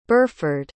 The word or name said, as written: Burford